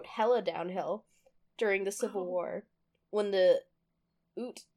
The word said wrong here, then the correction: Ute: I really should have looked up how to pronounce this, the Ute tribe allied with a U.S.